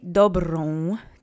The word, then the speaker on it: dobrą: Okay, let's say dobrą together three times. Try to roll that R. Accent is on the first syllable.